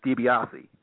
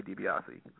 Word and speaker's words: DiBiase: Oh, it's, you know, it's the real authentic way, if you want to be authentic, is DiBiase. It's much like the wrestler Ted DiBiase, although it's spelled differently. But growing up, you know, I've heard everything from DeBias to DeBose. So it's but to be honest, I go by DiBiase.